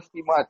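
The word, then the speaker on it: Štimac: Especially the fact that Igor Stimac has been appointed as, and by the way, the pronunciation is Igor Stimac. – Stimac.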